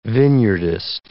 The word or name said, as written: vineyardist